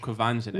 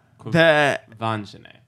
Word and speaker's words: Quvenzhané: It's Quvenzhané. – Well, Quvenzhané. – Yeah. The phonetic spelling, the Ven, was V-E-N. – It's spelled V-E-N.